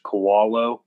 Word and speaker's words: Koalo: My Twitter handle is my last name, which is pronounced Koalo, kind of like a koala bear.